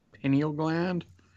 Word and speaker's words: pineal gland: I wish they would have done more into this whole, the pineal, how do you say it? – Pineal gland? – Yeah.